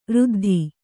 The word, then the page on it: ರುದ್ಧಿ: ruddhi